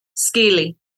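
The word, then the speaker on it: Skilly: Skeely, S-G-I-L-I, is how it's spelled phonetically in Cherokee. Up here, we put a little mountain slang to it. So it's S-K-I-L-L-Y, Skeely.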